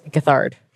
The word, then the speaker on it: Gethard: How would you say his last name? – Get Hard. And I would look you in the eyes while I say it.